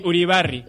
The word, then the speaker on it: Uribarri: Uribarri, ¿cómo se dice? – Uribarri.